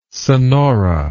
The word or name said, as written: sonora